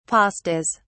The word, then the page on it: pastas: British English